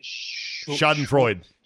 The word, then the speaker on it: Schadenfreude: Well, for Viking fans, this is a perfect case of, how do you say it? – Schadenfreude. – Schadenfreuden or whatever.